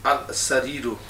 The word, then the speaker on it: السرير: السرير. – These are hurufus قمرية. شمسية, sorry. You don't pronounce that lam.